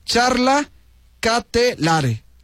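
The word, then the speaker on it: Charles De Ketelaere: Tendré que practicarlo, pero a bote pronto dice Charla Catelare. Ándale, así se dice.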